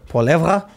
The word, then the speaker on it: Poilievre: Poilev. How do you pronounce that? Poilev. – Uh, Poilievre. And criticizing Justin Trudeau and his political allies.